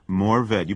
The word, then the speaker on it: Mourvèdre: You know, I have been seeing a wine on the shelf, and it's called Mourvèdre. What is this? – Mourvèdre. You pronounced that very well, by the way.